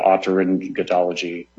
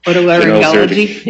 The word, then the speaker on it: otolaryngology: And I believe, you know, respondents have said that they don't practice in the, I'm going to butcher this word, but otolaryngology or otolaryngology. – There you go. Much better than I can.